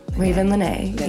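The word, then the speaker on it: Ravyn Lenae: I always say her name wrong. – Raven Lynae. Yeah.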